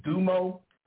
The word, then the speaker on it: Domo: Her name is Dumo, D-O-M-O, Dumo Wilson.